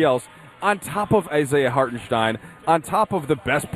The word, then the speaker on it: Hartenstein: I said, yeah, he's German. Hartenstein. That's how you say it.